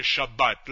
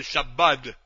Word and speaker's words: Shabbat: Para el Shabbat. La pronunciación tangerina más común es el Shabbat. Bueno, no con la D tan pronunciada. El Shabbat. La tetuaní, el Shabbat.